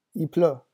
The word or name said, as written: il pleut